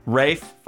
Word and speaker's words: Ralph: Reilly as Dr. Watson, Rebecca Hall, Rob Brydon, Steve Coogan, and Ralph Fiennes. I always say it wrong. – Rafe Fiennes. See, I always do it. I always do it. Rafe Fiennes.